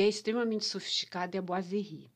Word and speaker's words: boiserie: Boiserie é uma palavra de origem francesa, a pronúncia é boiserie faz biquinho.